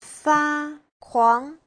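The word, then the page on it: fakuang: fa1kuang2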